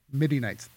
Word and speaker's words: Midianites: Midianites.